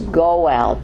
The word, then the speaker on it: goel: Okay, redeemer is goel, G-O-E-L, and that's what we are going to see in Boaz.